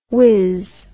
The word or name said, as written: wizz